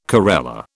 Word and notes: corella: British English

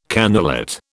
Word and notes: cannulate: British English

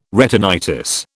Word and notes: retinitis: British English

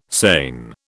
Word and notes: seine: British English

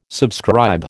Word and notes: subscribe: American English

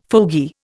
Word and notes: fogey: Indian

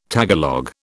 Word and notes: Tagalog: British English

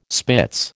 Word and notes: spitz: American English

